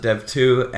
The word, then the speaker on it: dev.to: We have a website called dev.to. We pronounce it dev2.